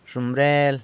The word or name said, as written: zhomrel